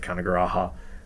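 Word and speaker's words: Canagarajah: I pronounced it Kanagaraja with a no hard J's here.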